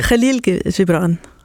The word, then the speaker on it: Khalil Gibran: det er den libanesisk-amerikanske Khalil Gibran, hvis jeg ellers udtaler hans navn rigtigt. Mm. Det ved jeg ikke, om jeg gør. – Næsten rigtigt. Khalil Gibran. – Khalil Gibran, siger du.